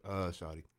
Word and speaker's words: shawty: Is it pronounced Shawty or Shorty? – Shawty. – Shawty, I figured.